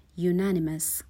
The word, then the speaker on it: unanimous: به تلفظ کلمه دقت کنین. Unanimous. اولش حرف یو رو داریم. ولی صدایی که میده در واقع یه حرف بی صدا هست. صدای یو میده. صدای آ نمیده.